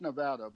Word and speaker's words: Nevada: She grew up in a place called Nevada, Missouri, spelled like Nevada, but pronounced Nevada, in the southwest corner.